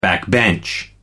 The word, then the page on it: backbench: American English